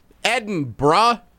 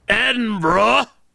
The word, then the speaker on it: Edinburgh: Edinburgh. – Edinburgh.